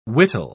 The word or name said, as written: wittol